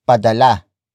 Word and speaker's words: padala: It is the focus marker that introduces the main topic of the sentence, and that is padala, padala, padala. Padala is spelled as P A D A L A.